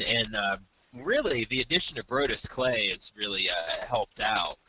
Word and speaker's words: Brodus: Is it Brodus? Brodus? – Yeah, Brodus.